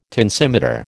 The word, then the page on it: tensimeter: American English